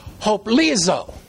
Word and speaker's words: hoplizo: Hoplizo. H-O-P-L-I-Z-O with the emphasis on the end of it. Hoplizo.